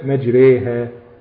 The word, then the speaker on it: مجريها: تمام، مجريها هي بتنطق، بتتنطق ألف، ألف ممالة لكن بتترسم ياء.